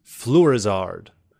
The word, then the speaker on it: Fleurizard: Wyclef Yves Fluorizard. W-I-C-L-I-F-F. Yves, as in Yves Saint Laurent. Y-V-E-S. Last name Fluorizard, as in Charizard, but Fluorizard. F-L-E-U-R-I-Z-A-R-D.